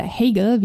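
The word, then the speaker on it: Heigl: die liebe Catherine Heigel oder Hegel, wie ihr Name auch mal ausgesprochen wird, wird ab der achten Staffel auch mitspielen.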